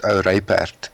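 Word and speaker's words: ou ryperd: It's actually very simple in my mother tongue, which is Afrikaans. It really, it's pronounced Ouraypert, and it really means it means an old faithful riding horse.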